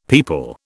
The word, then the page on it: peepul: British English